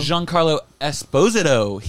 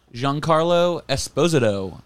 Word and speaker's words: Giancarlo Esposito: Steven, you pronounced his name wrong. – Is it Giancarlo? – Giancarlo Esposito. He's Italian, not Hispanic. – That's not true. – He's Colombian. – Isn't he like Haitian? – Giancarlo Esposito. That's how you pronounce it.